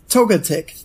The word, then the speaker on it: Togetic: If I looked at it funny, I'm sure I would say it wrong, but I know that you pronounce Togepi, so you're like Togetic.